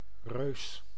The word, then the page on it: reus: /røːs/